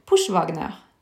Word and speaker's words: Pushwagner: Pushwagner betyr jo på Altså tysk da uttales det Pushwagner.